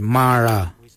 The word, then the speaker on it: marra: Number 9, Ma Kuju Kuju is spelled M-A-R-R, then it's G-O-O-D-J-A-L, and then again G-O-O-D-J-A-L. Ma Kuju Kuju. For the number 10, we say Mara. Mara, which is spelled M-A-R-R-A, Mara, for the number 10.